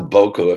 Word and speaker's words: Buku: And it's pronounced Boku if you're going to do it properly, but I don't. This is how I do it.